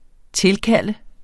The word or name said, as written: tilkalde